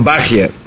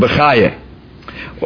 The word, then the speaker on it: Bachya: If it's Rabbeinu Bechai, it's Rabbeinu Bachya. As Kedai just to mention that in Eidus HaMizrach, in the Sfarish community, those that belong to the Eidus HaMizrach, they would pronounce it Rabbeinu Bachya, and that's very interesting. And in the Ashkenazish community. Not many are aware that the two communities, in the Ashkenazi community, we usually pronounce Rabbeinu Bechaye, or Rabbeinu Bechaye, like Bechayechoin, Bechaye, Rabbeinu Bechaye, and so on and so forth.